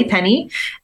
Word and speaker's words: Penney: So my Facebook page is at I Am Holly Penny, and my last name is spelled P-E-N-N-E-Y.